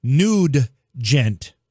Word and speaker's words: Nugent: Okay, so Matt says it rhymes with nugget, sort of. – Butt nugget? – So the name of the cat is not Ted Nugent, it's Ted Nugent.